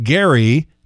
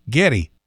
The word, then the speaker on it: Geddy: So his real name is Gary Lee. But his mother was a Russian Jew, and she pronounced Gary Geddy. You've heard people, you know, talk like that. It's like Gary becomes Geddy.